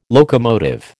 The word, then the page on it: locomotive: American English